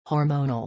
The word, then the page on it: hormonal: or